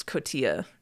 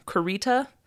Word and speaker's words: cotija: And then Paris Hilton could not pronounce cotija cheese. Let me see, I wrote some down. Corita, cautiwa, and cortita. She like danced all around it. She could not get it.